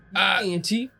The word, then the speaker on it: Yaganty: Y-A-G-A-N-T-Y. – Because you gotta. – Yiganti.